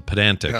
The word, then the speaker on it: pedantic: So pedantic. – Yeah ped, not pedant. Pedantic. – I've you know pedantic. You obviously put the emphasis on the middle syllable, but uh, look, we're doing exactly what he doesn't want us to do.